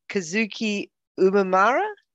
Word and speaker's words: Kazuki Umemura: We have for you Kazuki Umemura.